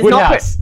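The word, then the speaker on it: Wodehouse: Yeah, W-O-D-E is Woodhouse. – Not... – Woodhouse. – Is not pronounced. – Which is funny, because it means the E doesn't carry out its traditional function. – It just doesn't do anything. – It's a silent E in the middle of a word. – It's a silent E, and then it transplants itself as an O earlier in the word. It's a magical E.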